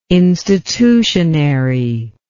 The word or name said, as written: institutionary